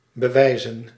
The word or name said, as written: show